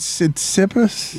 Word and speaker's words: Tsitsipas: So you made it Cispidus and it was Tsitsipas? – Yep.